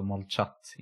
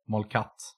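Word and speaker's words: Molchat (Молчат): Det är alltså Molchat, inte Molkat, har jag fått lära mig.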